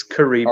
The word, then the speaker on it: Caribbean: It's Caribbean.